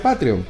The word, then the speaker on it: Patreon: E P-A-T-R-E-O-N.